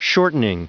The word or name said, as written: shortening